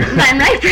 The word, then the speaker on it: Van Riper: It's just as plain as life. Van Riper, R-I-P-E-R. But as soon as I pronounce it, why, to everyone else around here, it's always Van Riper.